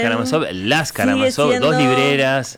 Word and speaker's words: Karamazov: Las, además, sí cambian sílabas. – Las Karamazov, ¿no? ¿Cómo? – Las... ¿Sí? Las Kamarazov, mucho. Las Kamarazón, un montón.